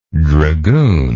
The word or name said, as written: dragoonage